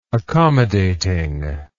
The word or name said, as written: accommodating